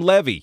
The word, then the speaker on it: Levy: Eugene Levy is 78, started out as a cast member of SCTV, pronounces his name Levy. Yes, he really does.